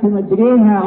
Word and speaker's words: مجريها: كلمة مجريها هنا الألف فيها ممالة إلى جهة الياء،